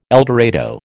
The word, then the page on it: eldorado: American English